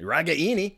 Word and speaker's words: Ragaini: Ragaini. – You've been saying it wrong the whole time. – I know. Sorry. I've been corrected.